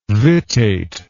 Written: vittate

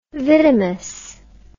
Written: vidimus

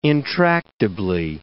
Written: intractably

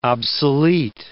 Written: obsolete

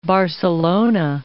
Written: Barcelona

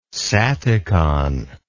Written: saticon

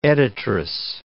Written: editress

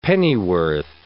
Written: pennyworth

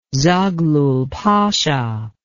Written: zaghlul pasha

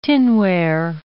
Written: tinware

